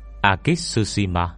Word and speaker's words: Akitsushima: Âm Nhật đọc là Akitsushima, tên gọi cũ của Nhật Bản.